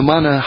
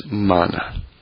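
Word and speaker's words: man (מן): Now, what was manna? Okay, and by the way, in Hebrew, it's spelled man. Mem nun, man.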